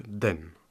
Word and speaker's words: 等: Alors, Xiaotai, attendre se prononce donc den en chinois.